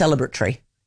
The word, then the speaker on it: celebratory: It's regulatory. It's celebratory. Okay? That's what it is. So it's not Tory. There's no Tory in there. Stop pronouncing the Tory. It's tree.